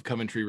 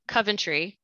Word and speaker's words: Coventry: So please, tell me about your hometown of Coventry, Rhode Island. – Well, first of all, I have to correct your pronunciation. Oh. You're saying Coventry, which is incorrect. I am from Coventry, Rhode Island.